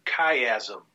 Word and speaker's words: chiasm: Skip past the outline a minute and look at that CH word, chiasm, is how you say that.